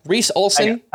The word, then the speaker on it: Reese Olson: Reese Olson. – I think I said that wrong. Let me correct myself. No, I said it right.